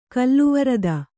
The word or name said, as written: ಕಲ್ಲುವರದ